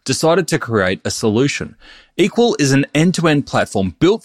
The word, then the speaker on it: EQL: That's EQL, pronounced equal.